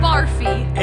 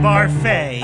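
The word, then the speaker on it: Barfée: returning after last season's tragic setback, William Barfy. – It's pronounced Barfay. There's an accent agil.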